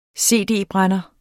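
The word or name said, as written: cd-brænder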